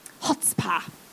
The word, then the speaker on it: chutzpah: And that's given me an extra bit of chutzpah. – Absolutely. – If I pronounced that correctly. – I think you did.